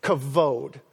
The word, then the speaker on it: kavod: Kavod. I know it has a B in it, but it's actually pronounced kavod.